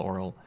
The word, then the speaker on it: aural: It's spelled A U R A L, oral.